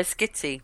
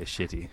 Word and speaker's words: Schitti: Oh, Valerio Schitti. – There we go. It's just my instinct to say shitty.